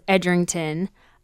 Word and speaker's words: Edrington: Edrington.